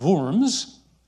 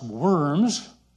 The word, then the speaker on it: Worms: It's called a diet, and it was held in the city of Worms, which in English we would pronounce Worms.